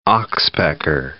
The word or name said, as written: oxpecker